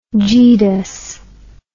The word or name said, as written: geedus